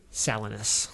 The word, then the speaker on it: Salinas: And excuse me if I'm mispronouncing Salinas Valley. – It's Salinas.